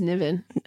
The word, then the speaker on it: Niven: I think it's Niven. – I know it is. I said it right the last few times.